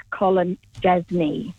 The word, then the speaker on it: Kolodziejczyk: His name is pronounced Jake Kolodziejczyk. You don't pronounce the D, and I am a cat supporter. – Kolodziejczyk. Is that it? – Kolodziejczyk, yes. – Okay. – So you don't, it's a silent D, but a live J.